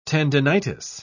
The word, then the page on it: tendinitis: British English